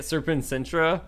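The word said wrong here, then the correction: Sectumsempra: How do you say that spell? – Secum Sempra.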